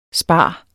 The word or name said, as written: SPAREN